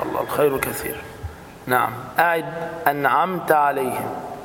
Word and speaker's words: أنعمت: انعمت تاء عندك خلاص مفتوحة فتنطق هكذا أنعمت عليهم، بعضهم يقرأ هكذا أنعمت عليهم، نعمت حتى هذه لهجة عند بعض الناس، لكن أنا رحت واستعملتها، هذا ليس صحيح، هذا لا صح. فأقول أنعمت عليهم، مهم جدا هكذا خلاص.